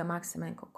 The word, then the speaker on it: Maksymenko: You can basically Google it saying Olga Maksymenko Coach, and the my last name you spell it with K S Y and then you write me an email and you share your I don't know concerns, you ask questions, etc.